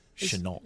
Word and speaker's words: Shenault: How do you say that name? – Shenault.